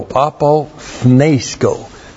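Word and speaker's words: apothnesko (ἀποθνῄσκω): N, long E, S, K, long O, apothnesko.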